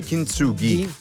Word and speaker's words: Kintsugi: Dovrebbe essere Kintsugi. Ghi, con la G dura, ghi, diciamo, di gatto. Ghi. Dovrebbe.